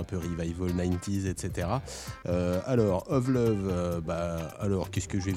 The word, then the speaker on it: Ovlov: Of Love, donc ça s'écrit O-V-L-O-V, sur Tentaculation tout de suite. Bien noisy, bien cradingue, cette fin du titre Land of Stevo, donc par Of Love, qui nous gratifie depuis quelques semaines maintenant de ce disque qui s'appelle Buds et qui vient tout juste de sortir, chers amis.